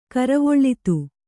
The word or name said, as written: ಕರವೊಳ್ಳಿತು